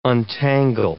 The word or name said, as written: untangle